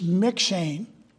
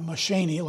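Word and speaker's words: M'Cheyne: But he's McShane. That's how you pronounce that. Not McShaney, like my reader pronounces it.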